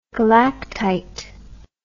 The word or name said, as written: galactite